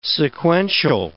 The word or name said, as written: sequential